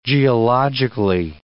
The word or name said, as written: geologically